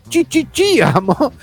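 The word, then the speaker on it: sí, amo: Su frase más característica es, obviamente, chi, amo. Pronunciada con la S como she, decía.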